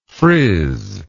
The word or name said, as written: frizzer